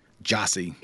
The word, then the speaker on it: Gyasi: G-Y-A-S-I, yep. Last line of the Friday poster. And it's pronounced what? – Jossie. Like J-O. It's either J-A-H... S-I or Got J-O-S-S-Y, it.